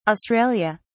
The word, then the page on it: Australia: British English